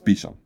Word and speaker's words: Beauchamp: His last name is spelled B-E-A-U-C-H-A-M-P, so I've always pronounced it as Beauchamp, because that's kind of what it looks like, but apparently it's Beesham.